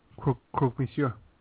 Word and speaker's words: Croque Monsieur: How do you say that sandwich? – Croque Monsieur.